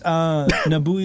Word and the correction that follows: Nobuo: Uematsu. No, it's Nobuo.